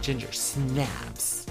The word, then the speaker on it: Ginger Snaps: How would you pronounce this? Ginger Snaps or Ginger Snaps? I think the emphasis is on the snaps. – Like Ginger Snaps.